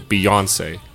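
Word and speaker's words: Beyoncé: of instead of the, the emphasis on the middle syllable. Beyonce.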